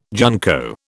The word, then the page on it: junco: British English